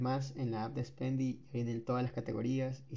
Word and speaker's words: Spendee: se, pronuncia Spendy, se escribe Spendy con doble e y eh,